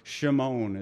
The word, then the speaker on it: Shimon: or Shimon is how you say it in the Hebrew.